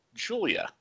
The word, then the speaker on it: Giulia: They're run by someone named Julia. G-I-U-L-I-A, like it's spelled very Japanese-y.